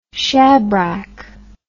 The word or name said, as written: shabrack